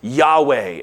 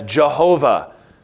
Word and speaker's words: Yahweh: The covenant name was probably pronounced Yahweh. It's sometimes been vocalized as Jehovah.